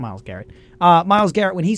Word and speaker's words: Myles Garrett: Yeah, I mean, we remember how uh, how serious everyone got about the Myles Garrett situation when he used his uh, uh, Myles Garrett. Did I get that name wrong? – Yeah, Myles Garrett.